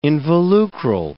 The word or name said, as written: involucral